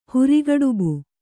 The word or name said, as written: ಹುರಿಗಡುಬು